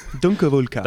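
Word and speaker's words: Donckerwolke: Luke Dunkervulka. Dunkervulka. – That sounds about right. – Yes. – It sounds like what it looks like.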